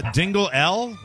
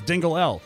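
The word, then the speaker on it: Dingle-L: Dingle L? – That's how it's spelled. Timothy Dingle hyphen L. So I guess it's Dingle L.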